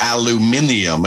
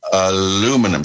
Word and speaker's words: aluminium: It's aluminium as opposed to aluminum, so it's spelled differently.